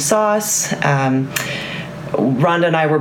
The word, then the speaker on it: SOS: SOS, which you know is phonetically pronounced sauce, and then the brand name sauce, which is a topping that you use to spice something up.